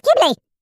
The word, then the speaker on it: Ghibli: I always say Ghibli. Like, I want to pronounce the H because I see it. Ghibli. Ghibli. Ghibli. Ghibli. Whatever. No, mate, it's Ghibli.